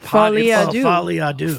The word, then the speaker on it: Folie à Deux: It's called that Joker 2 movie is called like Folie à Deux.